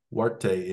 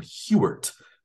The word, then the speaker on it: Huarte: It would probably be in Huarte in Spanish. He was the first Hispanic person to win a Heisman, but he pronounced his name, it was H-U-A-R-T-E. He pronounced his name Hewart instead of Huarte, probably to sound more Americanized, I guess.